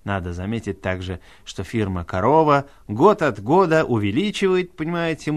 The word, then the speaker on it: Корова: Альбом для нас с вами, носителей русского языка, или, скажем, просто говорящих по-русски, представляет интерес еще и потому, что выпущен он фирмой «Корова», или, если поменять ударение, «Корова».